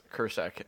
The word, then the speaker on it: Kresek: I have a friend whose last name is Kursek and it is spelled Kresek, which is exactly how many vowels Plzeň has.